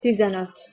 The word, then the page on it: tizenot: Hungarian